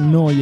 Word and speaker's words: Neu: Neu Radio, si scrive Neu, si pronuncia Noi e vuol dire nuova.